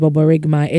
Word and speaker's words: borborygmi: That is baboregmai. It is spelled B-O-R-B-O-R-Y-G-M-I. Baboregmai.